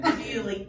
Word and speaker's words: Bewley: Bewley.